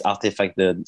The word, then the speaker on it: RTFKT: But so yeah, it's Artifact or RTFKT if you want, but that's how we pronounce it.